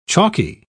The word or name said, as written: chalky